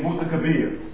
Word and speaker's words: Mutakabbir: We say Mutakabbir. But it's pronounced Mutakabbir.